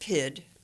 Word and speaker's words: pied: So it's pronounced PID. But it's spelled P-I-E-D, and there is one of those.